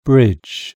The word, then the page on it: bridge: British English